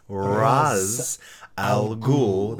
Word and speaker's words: Ra's al Ghul: In Arabic, it is pronounced Ra's al Ghul, the head of the demon. Now, that is not to say Ra's is incorrect, because Ra's al Ghul, Ra's is the Hebrew pronunciation, and people do argue that because the creators of Batman and Ra's were of Jewish descent, that they would have said Ra's.